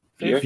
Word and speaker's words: Vue: V-U-E. – View. View. – Yeah. Do you not remember that I had this whole big thing where I thought it was pronounced "vu"? – Yes.